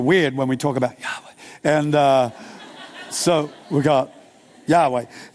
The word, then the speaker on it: Yahweh: It's spelled YHWH. It's, you can't really say it. It's Yahweh. Because YHWH are no breathe words. They're not consonants. That's why we've made it consonants, like Yahweh.